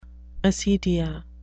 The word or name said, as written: acedia